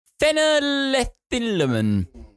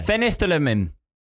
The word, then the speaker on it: phenethylamine: You really butchered that one. – Phenethylamine. – Say it with confidence, as if it's actually a word that you know how to say. – Phenethylamine. – Very good.